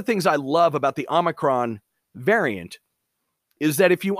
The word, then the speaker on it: Omicron: And Omicron is that really short one.